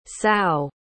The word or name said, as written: sao